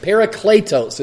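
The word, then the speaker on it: Parakletos (παράκλητος): Parakletos is the pronunciation of the Greek term.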